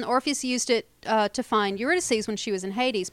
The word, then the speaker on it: Eurydice: He's talking about Orpheus and Eurydice.